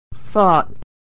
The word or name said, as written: fought